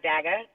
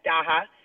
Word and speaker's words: Daha: My story today is South Africa's first Daha. That's how they say it, not Daga, Daha.